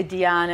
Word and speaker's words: Diane: They say Diane, and it rhymes with banana, like Diane Banana.